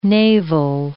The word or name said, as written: navel